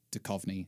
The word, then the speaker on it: Duchovny: But it's just straight up Duchovny. – Duchovny. – Yeah. – Is that how he says it? – Yes. And it's how the Wikipedia pronunciation guide told me to say it.